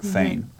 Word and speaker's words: Fain: Fain. Fain's his name. I said it wrong.